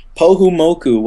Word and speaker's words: Pohumoku: Kukiaki Orchestra that, that you'd like for us to be able to share, and that's Pohumoku? – Pohumoku, which I said that right. – You did say it right.